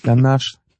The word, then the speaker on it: Ganache: Ich bin heute die Ganache, oder? – Ganache. Das war ja ein Versprecher, das war ja nicht falsch ausgesprochen.